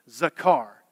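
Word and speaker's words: zakar (זכר): When you think of remembering something, that word in Hebrew is transliterated or pronounced out to remember, zakar.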